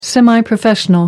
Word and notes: semi professional: American English

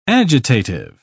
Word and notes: agitative: British English

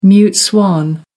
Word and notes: mute swan: American English

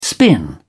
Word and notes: spin: British English

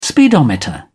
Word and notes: speedometer: British English

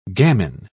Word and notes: gamin: British English

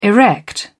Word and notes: erect: British English

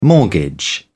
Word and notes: mortgage: British English

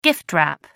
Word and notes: gift-wrap: British English

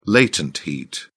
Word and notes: latent heat: British English

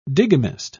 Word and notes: digamist: British English

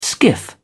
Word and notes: skiff: British English

